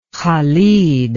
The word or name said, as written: Khalid